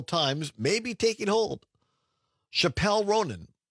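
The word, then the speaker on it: Chappell: I would have also guessed Chappelle based on how it's spelled.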